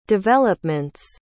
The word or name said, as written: development's